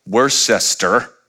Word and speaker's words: Worcester: It's spelled Worcester, pronounced Worcester.